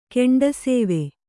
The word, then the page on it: ಕೆಂಡಸೇವೆ: kannada